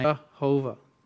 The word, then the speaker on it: Jehovah: Well, in German, when you translate the Yah sound, the Yod, it's a J. It's a J sound. So Jehovah. Because you take Yahweh, you pronounce the Yah as a Jah, and you put the vowels from Adonai, Jehovah.